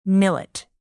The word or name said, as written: millet